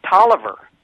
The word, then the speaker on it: Taliaferro: He discusses how names are, for example, if you look at the word Talia, the name Taliaferro, which is both an African-American and a Caucasian name in some parts of Virginia, it's pronounced Tolliver.